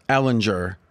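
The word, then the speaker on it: Ehlinger: Ellinger, is that how you say it? – Yes. – Is this an example? – Might be Ellinger. I've heard both.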